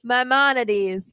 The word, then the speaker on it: Maimonides: The official beer of the Seven Line, official partners of the Mets, Maimonides. Did I say it right? – Maimonides.